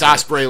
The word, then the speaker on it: Ospreay: I'm assuming it's Ospreay like the bird, but there's an extra A in there.